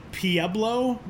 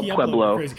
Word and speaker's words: Pueblo: They didn't I say Pueblo, but there's like an old guard of Colorado people that pronounces it Pueblo, which I think is the dumbest thing I've ever heard in my life. So we'll say Pueblo.